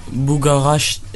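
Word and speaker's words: Bugarach: Bugaraç nasıl okunur Fransızca'da? Buga, ra, re, a, c, h. Bugaraç. Bir dakika, bir dakika dur hemen söyle. – Sonda e var mı? – H var, H var.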